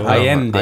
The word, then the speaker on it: Allende: Zpátky, no a asi bych, jestli bys nám řekl něco o osobnosti Salvadora Jendeho. – Tak Kdo Allende, to byl? Allende vlastně, že je... – Jestli jako, to říkám, vyslovuji to Salvador, Ano, je to Allende, ano, Allende.